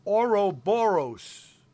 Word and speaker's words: Ouroboros: And then finally, Charm and Disaster with Ouroboros. – Yeah. Did I pronounce that right? – Yeah.